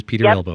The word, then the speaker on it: Elbow: And it is spelled E-L-B-O-W. It is Peter Elbow. – Just like your elbow.